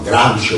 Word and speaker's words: Gramsci: Gramsci, noi pronunciavamo Gramsci o Gramsci alla francese, e lui diceva: meglio mettere Gramov alla russa.